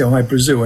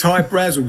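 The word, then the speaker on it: Hy-Brasil: People pronounce it I Brazil, and it's Brazil. Okay. – yeah. But it's High Brazil because that's how the Irish would pronounce it, because it's it's an island that's off the southwest coast of Ireland.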